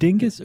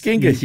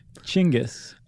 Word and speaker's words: Genghis: Do you pronounce it Dinghis or Genghis or Chinggis? – Are you being serious? – I know it's not Dinghis, but I've also seen it spelled in a way that would suggest you. – It's Genghis, you dingus.